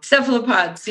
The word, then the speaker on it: Cephalopods: And that was on the team Cephalopod, right? I'm sorry, am I pronouncing that wrong? – Cephalopods, yeah.